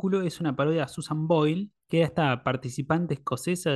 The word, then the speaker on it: Boyle: Y acá Susan, la original, se llama Boyle con Y, L, E. – Claro, claro, sí. E, es, como. – Se pronuncia igual, pero se escribe distinto.